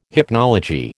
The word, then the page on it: hypnology: American English